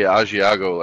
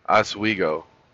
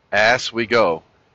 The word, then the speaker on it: Oswego: The rest of us say Asiago, like in the cheese. Oswego. Oswego. Oswego, right? Oswego.